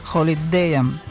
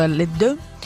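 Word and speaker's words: Hallyday: Следующий наш герой, его настоящее имя Жан-Филипп Лео Смет, которого весь мир узнал как Джонни Холидея. Во Франции его фамилию произносят как Алиде, так что как хотите, так и произносите.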